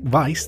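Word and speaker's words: Weisz: So number one, how was the entertainer Eric Weiss, that's spelt with a W, W-E-I-S-Z, so how was the entertainer Eric Weiss better known?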